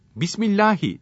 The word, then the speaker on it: Bismillahi: Sonra Bismillahi Allahu Ekber diyerek deveden başka hayvanın boğazının herhangi bir yerinden kesilir. Bismillahi derken H'yi belli etmek gerekir.